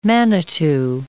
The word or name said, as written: manitou